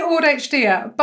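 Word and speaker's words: AuDHD: But let's backtrack a little bit, because I know that you have officially been diagnosed with ADHD and autism, how do you pronounce it? AUD-HD. – I say AUD-HD, but yeah.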